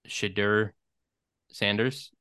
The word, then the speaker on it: Shedeur Sanders: And I think more people are hearing about Shedeur Sanders.